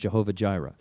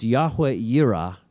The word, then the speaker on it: Yahweh Yireh: In English, it's often pronounced Jehovah Jireh. It's Yahweh Yireh, meaning God will provide, or God will appear.